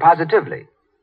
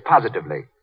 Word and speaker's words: positively: Mary Jane said positively. The word is positively, as in, the way Lux Flakes cut down stocking runs is positively miraculous.